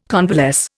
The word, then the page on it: convalesce: Indian